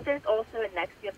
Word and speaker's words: NXIVM: Now, how do you say that again? NXIVM. How do you say that? – Nexium. – It is Nexium.